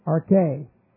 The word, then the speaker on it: arche: And this is A-R-C-H, long E, arche.